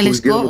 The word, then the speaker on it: Лешко: Ние сме тук от 10 години вече в село Лешко, само да е, Лешко, ударението тук. А, да, всъщност. Местния... – Да, Лешко.